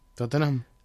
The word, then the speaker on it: Tottenham: O sea, Foyt el ficha al Tottenham, ¿cómo se dice? Tottenham. – Tottenham.